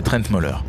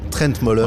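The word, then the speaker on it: Trent Møller: C'est très simple, c'est Trent Moller. – Trent Moller, voilà, avec Trent Moran.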